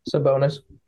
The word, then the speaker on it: Sabonis: It's like, the fact that he got angry with, Sabonis, or however you pronounce his last name. – Sabonis.